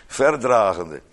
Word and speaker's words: verdragende: Ik lees altijd de klemtoon op ver. Verdragende. Verdragende elkander.